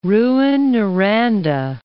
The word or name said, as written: Rouyn-Noranda